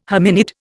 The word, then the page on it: hominid: Indian